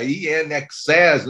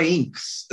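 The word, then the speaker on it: INXS: Assim, eu fui entender que o nome da banda era In Excess, né? In Excess, ou seja, em excesso, É anos depois, assim. Eu pronunciava Inks.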